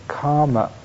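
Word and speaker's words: kama: And quite frankly, what it's really relinquishing is the world of the five senses, what in Pali we call the karma loka, the K long A M A world.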